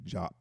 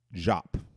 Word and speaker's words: Diop: But Dr. Sheikh Anta Diop, D-I-O-P, Diop, some call it, pronounce it Diop, but then again, I'm a member of ASCAC, and of course, we in ASCAC, we like to get it right. And he has this pronounced, the pronunciation is Jop.